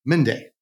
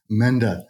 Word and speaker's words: Mende: I'm your host, and today my guest is Mende. Am I pronouncing that last name correctly? – Mende. Yeah. – Mende.